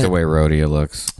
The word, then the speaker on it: Rhodia: And Rhodia. I don't know if that's how you say it. – I always just said Rhodia. – Rhodia. R-H-O-D-I-A.